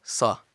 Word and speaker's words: Seo: It's spelled S-E-O, but it's pronounced Suh.